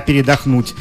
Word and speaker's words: передохнуть: Скорпионы, сегодня вам, наконец, дадут слегка передохнуть. Можете расслабиться. – Ударение правильно поставили. В слове «передохнуть».